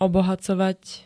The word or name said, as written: obohacovať